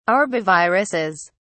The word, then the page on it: arboviruses: British English